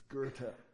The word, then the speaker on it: Goethe: And they, who's Goethe? Because it's spelled Goethe, you know. Who's Goethe? It's Goethe.